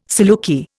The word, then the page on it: saluki: Indian